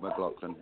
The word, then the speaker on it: McLaughlin: I think I remember I was 10 and my dad was on tour with Billy Cobham and John McLaughlin and how do you guys say it? McLaughlin or McLaughlin? I don't know how you say it, but McLaughlin. – That's it.